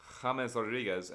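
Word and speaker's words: James Rodríguez: And I have James Rodriguez as my player to watch.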